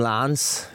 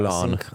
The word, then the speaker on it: Lens: Ve čtvrtek s Racingem Lens, jak jsem byl upozorněný, ta výslovnost. – Není to Lens. – Lens. – Lens. – To esko, no. Občas to tam není, občas to tam je.